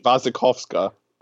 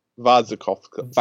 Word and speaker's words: Wasikowska: Wasikowska. – Is that really how it's pronounced? – Yeah. – Wasikowska. – Wasikowska. The W's are V's because it's Russian.